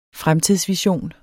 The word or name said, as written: fremtidsvision